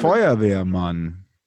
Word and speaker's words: Feuerwehrmann: Is it Feuerwehrmann or something like that? How do you say it in German? – Feuerwehrmann. – Say it again.